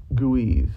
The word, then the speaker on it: Guweiz: Zeronis is actually a former video game illustrator, but, I think who I'm looking for is, hold on, Guiz. It's spelled G-U-W-E-I-Z.